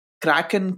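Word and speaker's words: Krkn: The project is called Kraken Chaos, and Kraken is spelled weird, but that's how it's pronounced.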